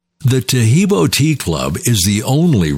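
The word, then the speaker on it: Taheebo: Tahibo is spelled T-A-H-E-E-B, like boy, O, then the word T, and then the word club.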